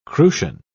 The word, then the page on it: crucian: British English